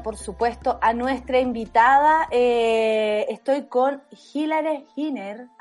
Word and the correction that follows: Hillary Hiner: ¿Así está? ¿Lo dije bien, Hilary? Mm, no, pero... cómo se dice. Hilary Heiner. Heiner. Ah, perfecto.